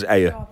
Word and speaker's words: Ayr: A-Y-R, Ayr. – Is that how they pronounce it, in Ayr? Is it Ayr? – Yes. Ayr. Pronounced it right.